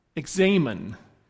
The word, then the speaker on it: examen: And it's a prayer of examen. And yes, that's how it's pronounced because I had to look it up and then Kathy had to test me on it.